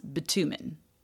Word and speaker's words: bitumen: In the 12th century, Europeans were in North Africa for the Crusades, and they learned about, I think it's pronounced bitumen. Bitumen. It's B-I-T-U-M-E-N.